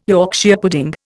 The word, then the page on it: Yorkshire pudding: Indian